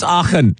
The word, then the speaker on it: Aachen: And how do you pronounce this? Aachen? It's Aachen, right? – We'll call it Aachen.